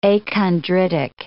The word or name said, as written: achondritic